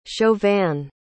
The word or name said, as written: chauvin